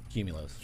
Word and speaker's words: Qmulos: Q-M-U-L-O-S. – Q-M-U-L-O-S .com. – Correct. – And it's pronounced? – Cumulos. – Cumulos.